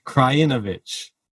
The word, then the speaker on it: Krajinovic: It's another 6-2, 6-1 from, and I apologize from the last episode, it is Krajinovic. So better pronunciation this time.